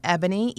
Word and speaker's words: Eboni: I can be reached on Twitter, Ebony, E-B-O-N-I, Dion, D-E-O-N, W-S-B. So Ebony Dion, W-S-B on Twitter. Facebook, it's Ebony Dion, W-S-B TV.